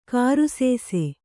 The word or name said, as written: ಗ್ರಾಸಿಸು